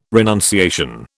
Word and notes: renunciation: British English

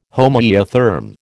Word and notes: homoeotherm: American English

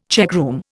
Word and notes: checkroom: Indian

